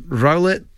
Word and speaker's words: Rowlet: Rowlet joins Ditto, Gengar and Snorlax in Cellutane's sofa range.